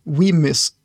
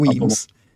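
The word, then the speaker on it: Wemyss: Och det är de som gör Weems, eller Weemys, säger vissa, men det ska uttalas Weems tydligen. – Det. – Enligt initierade källor. Nej, jag har hört det. Det var någon som korrigerade mig på det här en gång. Det ska vara Weems.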